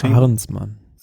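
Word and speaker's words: Arensman: Arensmann.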